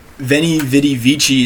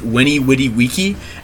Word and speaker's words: veni vidi vici: Anyway, so like Veni Vidi Vici is how it looks like it should be said, but you're supposed to pronounce it Weni Widi Wiki,